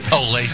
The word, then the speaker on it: OLE: Except it's spelled O-L-E. – Yeah. – Oh. – Olay 60. – Olay. – Is there a, yeah, that's Olay 60. – There's O-L-E 60.